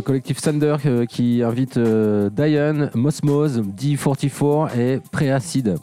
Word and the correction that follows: Prédacide: Prédacide, pardon, et High Sensor.